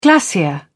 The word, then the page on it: glacier: British English